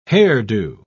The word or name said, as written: hairdo